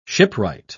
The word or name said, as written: shipwright